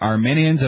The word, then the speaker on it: Arminians: About Arminians, and not to be confused with people from Armenia, but rather Arminians. Am I saying it right? – Yes.